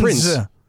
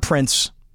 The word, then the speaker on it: Prinze: But Prinze. It's Prinze. Yeah, I mean, whatever. It's Prinze. Junior. – It's not Prince. – That's how he pronounces it.